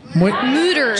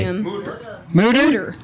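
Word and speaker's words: Mütter: No, we went to the Mortar Museum. Is that how you say it? – Mooter. – Just say it real exactly. – Mooter. – It's got the umlaut. The U.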